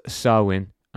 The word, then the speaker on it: Samhain: The holiday was called Samhain, and this is a tangent, but it's spelled S-A-M-H-A-I-N.